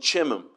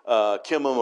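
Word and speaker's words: Chimham: Well, he had a son named Chimam, and he was, some pronounce it with a K, but Chimam or Chimam, he was a son of Barzillai, and he went to help David while he was in exile.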